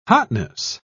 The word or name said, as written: hotness